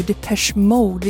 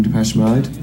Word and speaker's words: Depeche Mode: Men rätt är ju Depeche Mode. Vi kan till och med lyssna på bandets frontman Dave Gahan uttala bandnamnet själv här. – Hi, I'm Dave Gahan from Depeche Mode. – Ja, alltså Depeche Mode. Så nu har vi rätt ut det.